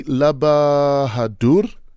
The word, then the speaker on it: Lalbahadur: We're hanging out with our guest this morning, Aditi Lalbahadur.